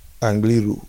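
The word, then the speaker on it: Angliru: How do you pronounce it? – Angaroo. – Angaroo, sorry. Not kangaroo, as I like to call it.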